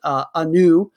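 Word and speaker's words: Anau: It's spelled A-N-E-W and A-N-A-U. I'm simply going to pronounce it Anu.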